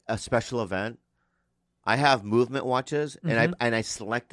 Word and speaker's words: MVMT: Movement. – Hey, what time is it? – MVMT time. – MVMT baby. – Time, movement. Baby. It's pronounced movement.